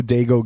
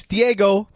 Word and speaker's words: Diego: Because he says, go, Dago, go. – Yeah. – And we're like, I'm like, it's Diego. Say you watch Diego.